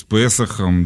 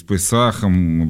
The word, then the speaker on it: Песахом: С Песохом, да, или с Песахом в разных рассеянных еврейских по-разному ударение ставится.